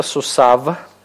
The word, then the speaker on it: сусав: Обратите внимание на то, что «йод» здесь не произносится. «Сусав». Почему не произносится? Две подряд согласных не могут быть, совершенно верно. Видите, что у йод нет никакой самостоятельной гласной, поэтому здесь нельзя ее произнести.